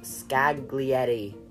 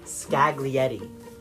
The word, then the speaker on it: Scaglietti: Ferrari 612 Scaglietti. That sounds like such a stupid name, but that's how it's pronounced. Scaglietti.